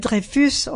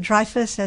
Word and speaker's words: Dreyfus: Alfred Dreyfus, or Alfred Dreyfus, as Americans pronounce it, who was Alsatian.